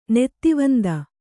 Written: ನೀತಿವಂತೆ

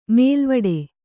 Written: ಮೇಲ್ವಟ್ಟ

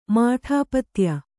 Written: ಮಾಠಾಪತ್ಯ

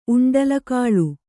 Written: ಉಂಡಲಕಾಳು

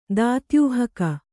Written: ದಾತ್ಯೂಹಕ

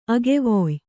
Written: ಅಗೆವೊಯ್

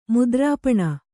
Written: ಮುದ್ರಾಪಣ

